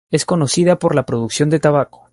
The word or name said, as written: tabaco